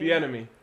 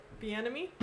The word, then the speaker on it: Bieniemy: Bieniemy. – Okay, Bieniemy.